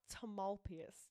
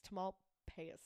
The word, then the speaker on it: Tamalpais: How do I say it? – Mount Tamalpais. Tamalpais. – Tamalpais. – Tamalpais. – Okay.